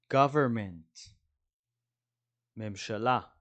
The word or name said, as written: government